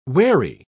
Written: wary

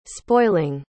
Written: spoiling

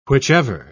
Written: whichever

